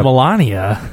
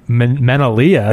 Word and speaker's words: Melania: Yeah, if you say, like, Menalia, it sounds really bad. – You just changed the inflection on one of the syllables. – Now it went, before it sounded like an airborne disease. Now it's, when you say it the other way, it sounds like an STD.